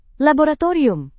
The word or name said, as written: laboratorium